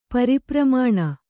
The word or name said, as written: ಪರಿಪ್ರಮಾಣ